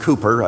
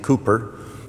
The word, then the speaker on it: Cowper: And it was Cowper. Actually, I think to be pronounced Cooper, I think the English folks pronounce his name Cooper, William Cooper, who wrote regarding people, and this would certainly apply to atheists, wouldn't it?